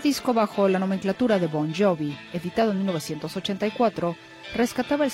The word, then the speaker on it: Bon Jovi: por lo que simplemente lo hizo sonar anglosajón al cambiar la G por la J y pronunciarlo Bon Jovi.